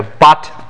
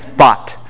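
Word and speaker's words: bot (בת): Like a bat mitzvah. Bat. Anyone know what the word for lie down with him is? Don't pronounce this bat, okay? It's bot.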